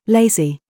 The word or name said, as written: FACE